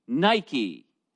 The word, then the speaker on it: Nike: The word for victory is the Greek word V-I-K-N. I'm not sure how you pronounce it in Greek, but the transliteration is N-I-K-E. Nike. Now, it's pronounced Nike, N-E-E hyphen K-A-Y.